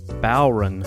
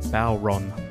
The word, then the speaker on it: bodhrán: I looked up the pronunciation, and it is in fact bodhrán. bodhrán. bodhrán.